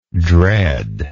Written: dreadness

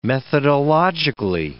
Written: methodologically

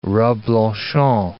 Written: Reblochon

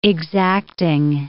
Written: exacting

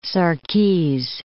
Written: Sarkese

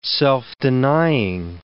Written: self-denying

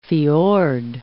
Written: fjord

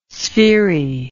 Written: sphery

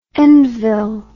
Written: Endville

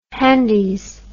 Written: handies

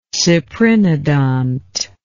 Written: cyprinodont

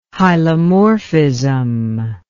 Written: hylomorphism

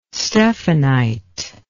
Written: stephanite

